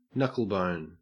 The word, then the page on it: knucklebone: Australian English